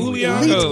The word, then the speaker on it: Uiagalelei: So you are in Uiagalelei.